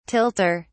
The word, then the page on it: tilter: British English